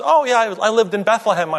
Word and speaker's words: Bethlehem: It's two syllables. – Bethlehem. Yes. – Why is that? – Bethlehem. – Well, if you think about Bethlehem, it's like Bedlam. Same idea, sort of Bedlam, Bethlehem.